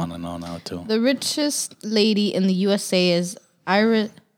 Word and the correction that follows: heiress: How do you say this word? – I knew you weren't going to know. Heiress.